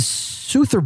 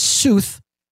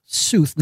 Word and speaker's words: Sooth: It's the Soother Bowl. Now, my friend's company is Sooth. As in truth, sooth, not soothe, sooth. So add an R and a bowl, Soother Bowl. – Rhymes with and, tooth.